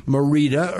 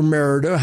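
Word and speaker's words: Mérida: It's about 105 miles east of Merida or Merida, however you would prefer to pronounce it.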